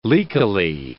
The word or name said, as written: leakily